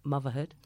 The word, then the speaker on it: Muvvahood: She's in today to talk to us about her play, Muvvahood.